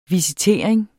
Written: visitering

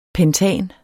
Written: pentan

